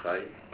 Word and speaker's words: Rayi: My name is Mandla Khai. I'm the chairperson, Khai is spelled R-A-Y-I.